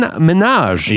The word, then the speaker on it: Minhaj: Also coming up later on the show, we talked to Daily Show senior correspondent Hasan Minhaj. – Yeah. – He's coming up later. It's pronounced like Nicki Minaj.